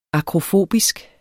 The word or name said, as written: akrofobisk